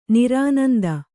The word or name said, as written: ನಿರಾನಂದ